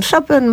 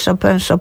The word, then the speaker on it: Chopin: Oczywiście wracamy do filmu, zostajemy już przy filmie Chopin. Tak to trzeba wymawiać, prawda? To już wyjaśnimy. Chopin? – No, to czy sobie ułatwiamy? – Dowolnie. Może być Chopin, może być Chopin.